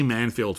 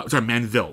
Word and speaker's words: Manville: The new movie is directed by Anthony Fabian, and it stars Leslie Manfield from Phantom Thread. Manville.